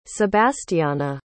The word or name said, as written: sebastyana